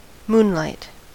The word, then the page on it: moonlight: American English